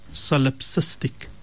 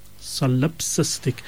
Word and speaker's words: solipsistic: The word we were looking for today is the word solipsistic. Solipsistic. It's spelled S-O-L-I-P... IP, then SISTIC. So again, SOLIP, SISTIC, solipsistic.